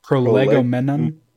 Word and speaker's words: Prolegomenon: How do you say this word? – Prolegomenon. – Prolegomenon. There you go.